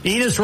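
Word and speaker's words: Enes: Enos rhymes with penis.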